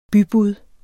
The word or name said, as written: BYBUDDET